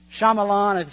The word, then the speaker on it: Shyamalan: Night, I think you say Shyamalan is how you say his name, where they create a movie and they direct it, but then they have a cameo appearance in it.